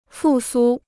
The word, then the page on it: 复苏: fù sū